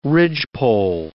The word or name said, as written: ridgepole